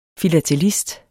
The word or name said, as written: FILATELISTER